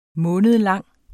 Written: månedlang

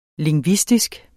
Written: LINGVISTISKE

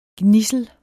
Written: gnidsel

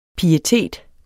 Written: pietet

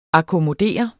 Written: akkommodere

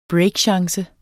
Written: breakchance

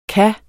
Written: kunne

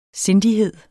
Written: SINDIGHED

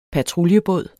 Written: patruljebåd

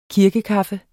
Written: kirkekaffe

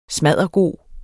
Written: SMADDERGODT

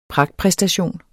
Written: pragtpræstation

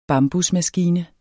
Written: bambusmaskine

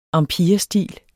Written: empirestil

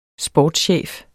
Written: sportschef

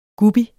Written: guppy

